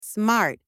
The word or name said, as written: smart